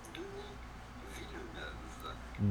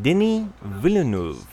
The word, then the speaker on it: Denis Villeneuve: Also is the typical French-Canadian French pronunciation, Denis Villeneuve. – Denis Villeneuve.